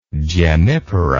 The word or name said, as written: gemmipara